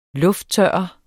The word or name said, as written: lufttørre